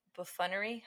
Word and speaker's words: buffoonery: Number one, the buffoonery. How do you say buffoon? A clown. – Buffoonery. – Thank you.